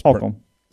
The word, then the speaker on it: Holcomb: I'm learning how to pronounce that. – Holcomb. – See, I was pronouncing it incorrectly.